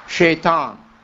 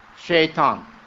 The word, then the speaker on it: Shaitan: And it's the same word, but we don't pronounce the word with S. We pronounce it with S-H. Shaitan. Shaitan.